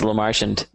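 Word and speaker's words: Lemarchand: It's pronounced LaMarchand. That's actually not a joke.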